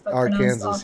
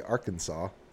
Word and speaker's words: Arkansas: Arkansas, yeah. We can talk about that, actually, if you want to know the reason why we say Arkansas.